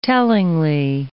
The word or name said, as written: tellingly